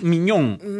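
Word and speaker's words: Maignan: Mike Mignon. O sea, como Mignon. Bistec al Mignon.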